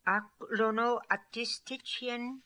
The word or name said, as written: Aklonoatistitchian